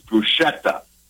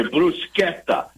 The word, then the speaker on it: bruschetta: Now, you will hear people, this is bruschetta. You should always correct them. That's not the right way to say this word. – Okay. – Bruschetta. It's a hard K, bruschetta.